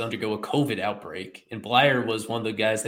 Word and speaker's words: Bleier: They swapped Matt Barnes from the Red Sox, went over in exchange for lefty reliever Richard Blyer. Or I don't know if I'm pronouncing that right, if it's Blyer or Blyer. – You got it.